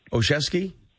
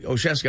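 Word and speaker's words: Olszewski: Say his name for me. His last name. Ozeski? Well, I got it right. Ozeski, all right.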